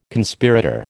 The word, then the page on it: Conspirator: American English